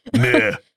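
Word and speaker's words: Meux: So located in St. Giles is the Horseshoe Brewery, owned by, it's spelled M M-E-U-X. – Mux? Muix? – It's pronounced, I looked it up. Bleh. Stupid French language. – Meh. – Meh. – Meh. – I literally typed in my notes pronounced M-U-H.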